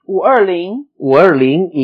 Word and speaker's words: 五二零: La primera era un 520 que se pronuncia... – 五二零 y que significa...